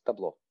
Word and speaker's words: Tableau: Так как она пишется на французский манер таблеау. то его часто очень читают каким-то там странным образом. А, оно на именно американский манер, ну, ну читается как просто табло.